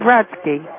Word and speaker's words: Brodsky: I said Brodsky. – How do you spell that? – B-R-O-D-S-K-Y. – B B-R-O-D-S-K-Y. – No, S-K-Y.